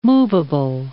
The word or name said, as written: moveable